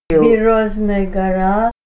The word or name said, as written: Birch Hill